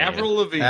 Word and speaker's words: Avril Lavigne: This song was covered by Avril Lavigne. Avril. I don't know how to say her name. – Avril Lavigne.